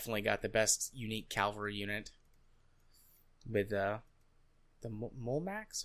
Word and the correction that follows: Mamluk: or Mulmuluk? Mamluk? Yeah, Mamluk. I just looked it up to make sure I was pronouncing it right.